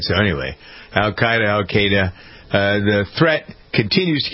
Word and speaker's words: Al-Qaeda: I say Al-Qaeda. – Al-Qaeda. It's spelled A-L-Q-A-E-D-A. So Al-Qaeda. Al-Qaeda, the E influences the A sometimes, depending. I mean, English is a strange language.